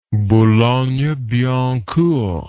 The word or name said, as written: boulogne billancourt